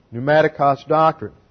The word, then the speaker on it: pneumatikos: P-N-E-U-M-A-T-I-K-O-S. Pneumatikos doctrine. Final accent is on the final syllable.